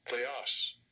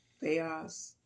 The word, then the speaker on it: Theos: Theos. Theos. – Theos. Theos. That's how we say God in the Greek.